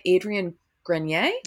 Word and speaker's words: Adrien Grenier: It's about this guy played by Adrien Grenier.